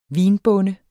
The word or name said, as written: vinbonde